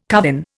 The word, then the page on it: covin: Indian